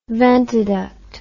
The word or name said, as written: ventiduct